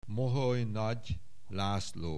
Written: Moholy-Nagy László

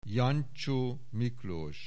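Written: Jancsó Miklós

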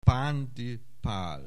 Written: Pándy Pál

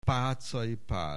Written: Pátzay Pál

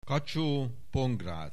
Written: Kacsóh Pongrác

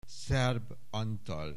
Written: Szerb Antal

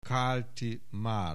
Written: Kálti Márk

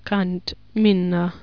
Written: Canth Minna

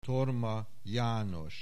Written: Thorma János

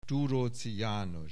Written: Thuroczy Janos

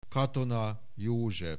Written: Katona, József

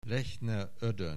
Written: Lechner Oedoen

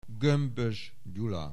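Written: Gömbös, Gyula

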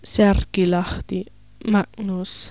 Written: Särkilahti, Magnus

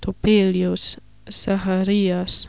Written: Topelius, Zacharias

